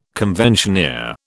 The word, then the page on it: conventioneer: British English